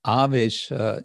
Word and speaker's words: avesha: And then Vish becomes Vesha, and then Avesha is a kind of strengthening prefix, long A.